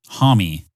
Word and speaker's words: Homme: That lead singer's name is pronounced Josh Homme. The last name looks like Home or Om, like French man.